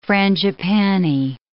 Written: frangipani

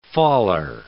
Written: faller

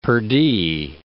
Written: perdie

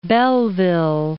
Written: Belleville

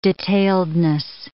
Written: detailedness

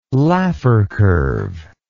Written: Laffer curve

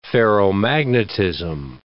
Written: ferromagnetism

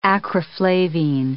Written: acriflavine

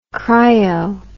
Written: cryo

